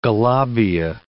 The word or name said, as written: galabieh